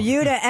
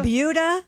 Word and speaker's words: Buda: It's Buda? – Buda. – Buda. – Skip over. – Buda. Like Peter Wolf. – It's spelled B-U-D-A, and I looked it up. It is pronounced like beauty.